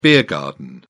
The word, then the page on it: beer garden: British English